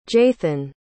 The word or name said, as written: jathan